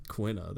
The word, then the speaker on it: Quina: Quina. How do you pronounce it again? – Quina, there you go.